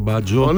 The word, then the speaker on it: Μπάτζιος: Όχι ο Ρομπέρτο Μπάτζο. – Ναι, είπαμε. Θα το φάμε το γιώτα κάποια στιγμή. Γιατί δεν προφέρεται το γιώτα. – Δεν προφέρεται, όχι.